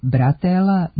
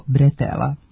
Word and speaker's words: bretela: Međutim, naramenica kao deo grudnjaka, potkošulje, kombinezona, letnje majice ili haljine, remen ili kaiš za nošenje tereta nije bratela, nego bretela. Potiče od francuske reči bretel, koja u izgovoru ima nemo e, a ono se u naš jezik prenosi kao e.